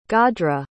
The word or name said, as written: gadhra